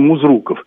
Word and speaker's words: Музруков: У нас принято произносить эту фамилию Музруков. У нас есть проспект Музрукова, у нас проводятся лыжные соревнования, мемориал Музрукова. Так как все-таки правильно произносить эту фамилию? Спасибо. – Спасибо. – Безусловно, так, как ее произносят в Сарове. Других вариантов нет, я ошибаюсь, исправлюсь.